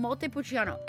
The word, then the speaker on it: Montepulciano: Again, Montepulciano, Montepulciano.